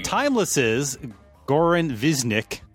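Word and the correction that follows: Višnjić: Am I saying that right? – Nope. Visnish.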